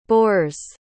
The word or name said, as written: boers